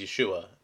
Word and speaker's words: Yeshua: Matthew 5:17-19 states, and this is coming from the mouth of Jesus, who I will refer to as Yeshua, as that's how his name is pronounced in the Hebrew.